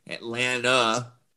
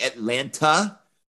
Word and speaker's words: Atlanta: That's how you pronounce it, by the way. Atlanta, N-U-H on the end. I'm from Atlanta. If you say Atlanta, that's an automatic sign you're not from Atlanta.